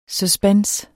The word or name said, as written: suspense